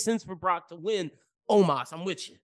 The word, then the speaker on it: Omos: Okay, so I'm going with Omos. – Me too. – Let me say it correctly. Omos.